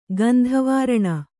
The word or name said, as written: ಗಂಧವಾರಣ